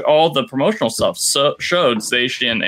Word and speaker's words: Zacian: And I found out on that day that what we've been calling Zacian is actually pronounced Zacian. That CI is apparently doing some SHs work there.